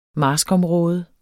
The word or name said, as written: marskområde